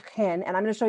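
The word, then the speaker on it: chen (חן): Well, I'm going to pronounce it the way they do it in Hebrew, chen.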